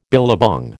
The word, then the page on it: billabong: American English